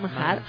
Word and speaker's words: Manhattan: ¿Lo dije bien? Manhattan. – Sí.